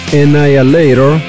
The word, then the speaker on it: Annihilator: la pronunciación lo más correcta posible sería Annihilator o Annihilator.